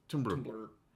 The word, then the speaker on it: Tumblr: Am I saying it right? There's no E. – Saying it right. – Tumblr.